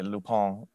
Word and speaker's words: Lupin: I'm watching Lupin right now, or as people say, Lupin, if you want to pronounce it correctly.